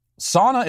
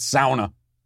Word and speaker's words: sauna: Orange Banana says, hey, Matt, sauna is pronounced sauna. S-O-W-N-A. What? Sauna? Sauna. I was just sitting over there in the sauna. That's not how it's pronounced. It's sauna. Sauna. A-U-A. Sauna. Like, I may not have graduated college or even gone to college, but I know it's not sauna.